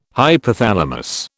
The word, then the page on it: hypothalamus: British English